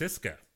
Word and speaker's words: CSKA: I think it's pronounced Siska, by the way. – Okay. – Siska Moscow.